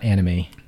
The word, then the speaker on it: anime: So it's an anime. It's pronounced anime, not anime. So if you're saying anime, you're pronouncing it wrong.